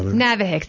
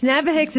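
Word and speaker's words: NAVHCS: Navihicks. Navihicks is the acronym.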